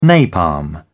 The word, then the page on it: napalm: British English